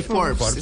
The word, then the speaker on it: Forbes: Forbes, ¿lo dije bien? – Sí, ah, Forbes, está bien.